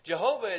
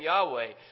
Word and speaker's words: Yahweh: Jehovah is actually a mispronunciation of the name Yahweh.